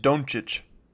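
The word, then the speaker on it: Dončić: I said that right? You have to say it, Doncic. – Yeah, I mean, like, I kind of text and drawl it, but yeah, I believe that's the proper pronunciation.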